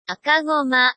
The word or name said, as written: akagoma